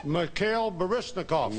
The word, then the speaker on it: Mikhail Baryshnikov: Mikhail Baryshnikov. – You got it. And you got, I love your Russian pronunciation.